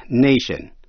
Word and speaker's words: nation: Cũng nhớ lại cách đọc nation, danh từ N-A-T-I-O-N, nation. Khi là danh từ đọc A, nation. Tĩnh từ national.